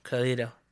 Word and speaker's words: Karlhto: K-A-R-L-H-T-O, Karlhto. It's pronounced Karlhto with an H.